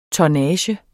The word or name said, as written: tonnage